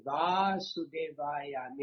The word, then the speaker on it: Vasudevaya: Is Bhagavate, Va is a long A, Vasudevaya means the son of Vasudev.